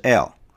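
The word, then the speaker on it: El， (אֵל): 大能者发音是 El，这是上帝的名字之一，强调的是上帝的能力。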